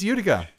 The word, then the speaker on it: Utica: Utica. Dang it! You got me! – Told you, Steve. It's Utica.